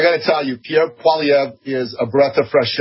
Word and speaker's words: Poilievre: Pierre Poilievre? Is that right? Poilievre? – Poilievre.